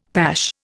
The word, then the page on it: pash: Indian